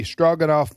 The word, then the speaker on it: Stroganoff: It's spelled, the Russian spelling is Stroganov. – Stroganoff. – But the English, we do it Stroganoff, which is phonic, stroganoff. But it's Russian.